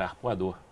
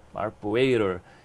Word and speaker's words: Arpoador: O nosso nome anterior era Arpoador. Então a gente chegava nas feiras lá fora, nos eventos e o, e, e o americano conseguia falar Arpoator.